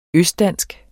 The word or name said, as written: østdansk